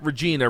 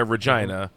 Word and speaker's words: Regina: But we were going, we were driving from Saskatoon to Regina, and they pronounce it not Regina, Regina. They call it the city that rhymes with fun.